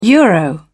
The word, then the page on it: euro: British English